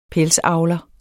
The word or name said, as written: PELSAVLERE